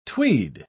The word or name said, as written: tweed